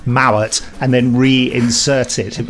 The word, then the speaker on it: Mowat: I must be careful to pronounce his name correctly, because I know a person called Sarah Mowat, and her name is spelt exactly the same. But she says Mowat, he says Mowat.